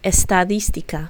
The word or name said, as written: estadistica